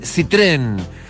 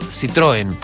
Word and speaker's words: Citroën: Y por supuesto también les recuerdo que si tu Citroën o Citroën, lo digo siempre de esta forma porque la pronunciación correcta de la marca es Citroën, pero la conocemos todos por Citroën.